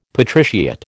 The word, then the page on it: patriciate: American English